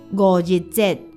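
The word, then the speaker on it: 五日節: 清明节的台语是清明，清明，而端午节的台语念作五日节，五日节，就是五日节。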